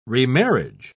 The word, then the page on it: remarriage: British English